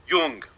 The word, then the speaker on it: Jung: J is pronounced like a Y. The U is pronounced like the U in book. Jung.